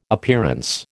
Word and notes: Appearance: American English

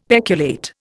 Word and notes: peculate: Indian

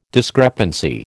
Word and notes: discrepancy: American English

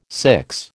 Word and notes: six: American English

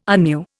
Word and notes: anew: Indian

